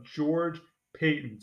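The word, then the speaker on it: George Paton: George Payton, spelled differently than Sean Payton, but pronounced the same.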